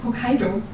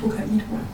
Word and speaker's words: Hokkaido: Hokkaido, nicht Hokkaido.